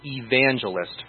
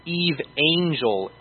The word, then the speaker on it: evangelist: Listen to how I pronounce the word evangelist for a moment. Evangelist. Evangelist. So the word angel is inside the word evangelist.